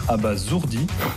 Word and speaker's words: abasourdi: Et ce qui m'abasourdit le plus, on prononce abasourdi, c'est les fautes qu'au final personne ne remarque.